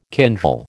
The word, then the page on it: kindle: American English